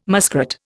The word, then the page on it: muskrat: Indian